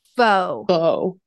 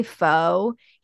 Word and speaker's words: pho: actually, we went and got food at a place called, it's closed now, Pho Grand. You know Pho Grand? – R.I.P. – I know. R. And R. I. Pho Grand. I know. And I pronounced it Faux. – Instead of pho.